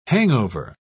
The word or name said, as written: hangover